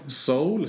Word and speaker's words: Seoul: Till, uh, ursäkta uttalet nu alla sydkoreaner, men Seoul hur säger man? Soul säger man. Soul, ja, ja.